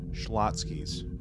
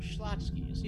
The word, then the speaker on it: Schlotzky's: Schlotzky's. Yep. – Oh, Schlotzky's. Yeah. – Yeah. Schlotzky's.